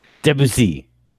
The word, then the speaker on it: Debussy: I hate to give it away here, but it's clearly Achille Claude Debussy, the French composer. – Debussy, excuse me, as you know, one of the first impressionist composers. – Say it right, Frenchie.